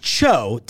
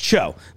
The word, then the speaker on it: TCHO: These are in stores now from, it's pronounced Cho, T-C-H-O. I know you've also seen this product and gone, how do you pronounce that? Is it T-C-H-O? No, it's Cho, like Margaret Cho.